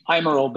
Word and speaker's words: Eimerl: Eimerl, but nobody pronounces Eimerl.